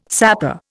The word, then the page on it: sapor: Indian